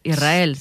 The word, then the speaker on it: Israel: Y la S con la R es difícil de pronunciar. La gente dice Israel, es verdad. – Que suene la S. Israel, sí, es difícil de pronunciar.